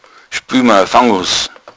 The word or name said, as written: fangus